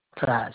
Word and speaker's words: Clyde: G-R-I-F-F-I-N. – Thank you. And the first name again? – Clyde, C-L-Y-D-E.